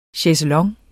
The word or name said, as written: chaiselong